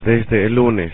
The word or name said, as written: desde el lunes